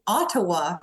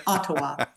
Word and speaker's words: Ottawa: And I'm so happy that you're saying Ottawa, because so many people say Ottawa, or, you know, they kind of smash it all together. But you say it like a proper Canadian, Ottawa.